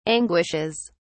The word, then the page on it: anguishes: British English